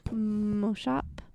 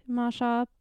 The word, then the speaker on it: Maushop: Sorry, I keep thinking you're saying Moshop, but you're not saying... – Well, it's spelled M-A-U-S-H-O-P. – Moshop. – Moshop.